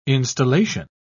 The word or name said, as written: installation